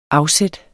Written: afsæt

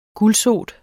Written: gulsot

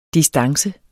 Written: distance

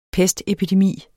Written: pestepidemi

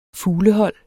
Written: fuglehold

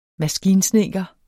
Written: maskinsnedker

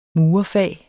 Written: murerfag

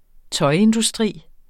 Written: tøjindustri

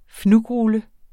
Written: fnugrulle

